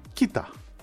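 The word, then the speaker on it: Keita: Ο Ολλανδός που ρώτησα με τον Άγιαξ για τον Κέιτα, που τον λέμε εμείς Κέιτα και γενικά τους προφέρουμε Κέιτα. Κοίτα. – Κοίτα τον προφέρουν. Εντάξει, Ολλανδοί είναι. Κοίτα.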